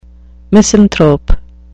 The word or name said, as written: misanthrope